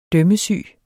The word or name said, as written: dømmesyg